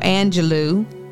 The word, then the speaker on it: Angelou: Miss Maya Maya Angelou. That's how you say it. I want to make sure it's Angelou, Doctor Maya Angelou.